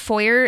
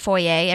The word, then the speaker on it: foyer: Side note, someone told me once that foyer is actually pronounced with an R. It's not foyer. Everyone tries to make it like fancy because it's like they think it's French or something, but they would actually pronounce it foyer.